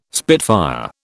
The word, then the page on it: spitfire: British English